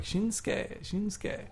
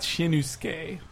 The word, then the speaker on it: Shinsuke: The U is silent, like the U's quiet, like Shinsuke. It's not Shinusuke. That's the wrong way.